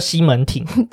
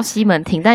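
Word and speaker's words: 西門町: 那讲到这个町呢，其实现在的这个西门町啊，其实这个字应该要就是念作町，它叫西门町。 – 对，应该叫西门町，但因为我们就是呃积非成是吧，就一直都叫它西门町。 – 对对对，所以其实西门町它算是一个区，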